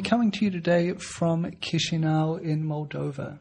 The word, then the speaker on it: Chisinau: The problem with Chisinau is that the way you say it isn't anything like the way you write it.